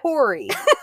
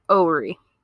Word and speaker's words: Horry: it's spelled H O R R Y. But they say on, I think it was, I think it was Google that I looked it up on. The pronunciation on there says Ori.